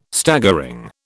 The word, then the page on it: Staggering: British English